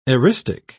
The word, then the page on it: eristic: British English